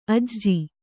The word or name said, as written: ಅಜೆ